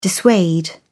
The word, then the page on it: dissuade: British English